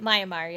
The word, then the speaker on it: Myanmar: like Myanmar. Am I saying that right? – Myanmar, yeah.